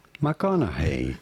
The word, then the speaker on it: McConaughey: Matthew McConaughey.